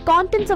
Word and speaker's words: content: The first syllable rhymes with Don and John, while the second syllable sounds like tent. But when content is used as an adjective or verb, the stress shifts to the second syllable. Content. Here it means being satisfied or pleased with what you have. For instance, the children were content with leftovers for breakfast. They had to content themselves with what was available. Got it? Remember, first syllable stress for the noun, second syllable stress for the adjective and verb.